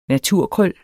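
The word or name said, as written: naturkrøller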